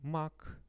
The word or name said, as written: mak